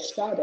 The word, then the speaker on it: Sade: It's spelled like Jade Wait. With an S in front of it. No J's. It's S-A-D-E.